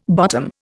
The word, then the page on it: bottom: Indian